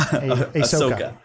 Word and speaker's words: Ahsoka: And but constantly people come back and say Osaka's uh, Osaka. Am I saying that right? A- Ahsoka. – Ahsoka. Ahsoka. Ahsoka. Oh, Ahsoka.